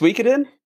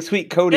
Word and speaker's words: Suikoden: And it's pronounced Suikoden. I've been saying Suikoden.